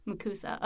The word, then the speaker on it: MACUSA: MACUSA. MACUSA. Oh, that's right. Okay. That sounds like an S. Just like FLOTUS or POTUS of the United yeah. States.